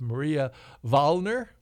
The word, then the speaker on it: Maria Wallner: Victoria Ricci plays the role of Maria Wallner. Am I pronouncing, is my German up to snuff? – Yeah, that's good.